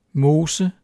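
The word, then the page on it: mose: Danish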